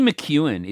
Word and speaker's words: McKeown: Kaylee McEwen is spelled like McEwen is spelled M C K E O W N. Like it doesn't look like that.